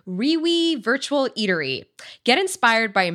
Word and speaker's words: REWE: It's spelled R-E-W-E, Riwi Virtual Eatery.